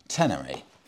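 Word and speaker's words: Ténéré: I was pronouncing the Yamaha Tenere wrong as well. It's the Yamaha Tenere.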